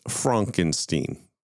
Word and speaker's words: Frankenstein: It's spelled Frankenstein, but in the movie, it's pronounced Frankenstein.